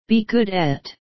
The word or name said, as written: be good at